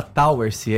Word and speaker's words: Towersey: É, se escreve Towessey, mas se fala Towersey, é em inglês.